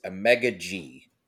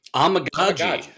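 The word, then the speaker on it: Amegadjie: I believe it's Omega G. Does that match up with what you? – Omagaji. – Okay. Omagaji. See, I'm so bad at pronouncing everything. Okay. Omagaji. I like it. I can do that. Omagaji. Like, oh my God, G.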